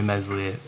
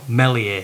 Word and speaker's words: Meslier: Yeah, one little thing with Meslier. Well, Melier, if you want to say it right. – It's not an S in his name, we're sticking with it, alright.